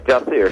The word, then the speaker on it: Jacir: Before we get into the new movie that's supposed to be opening up in theaters, is it Jacir? Am I pronouncing that right? – Yeah, Jacir.